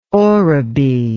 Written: oribi

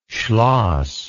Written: schloss

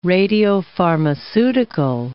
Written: radiopharmaceutical

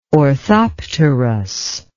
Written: orthopterous

